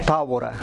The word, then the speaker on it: Tabora: Tabora. – Leonel. – Somebody say Leonel Tabora, but it's, yeah. But no, you no use the accent here, but in the A.